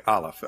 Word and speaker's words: Aleph: Did I say it wrong? How do I say it? Okay, Aleph. Okay, great.